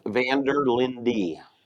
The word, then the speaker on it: Vanderlinde: Jeff Vanderlinde. – Vanderlinde. – Just like it's spelled.